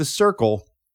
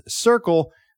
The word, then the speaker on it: Cyrkle: but instead he gave it to The Circle. By the way, their name is spelled, if you're looking to look it up, C-Y-R-K-L-E.